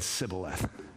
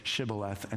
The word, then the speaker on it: Shibboleth: Because the tribe of Ephraim could not pronounce Shibboleth. They said Sibboleth. We don't know if it's a genetic disorder. We don't know if it's just cultural, but they could not say Shibboleth.